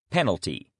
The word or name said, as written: penalty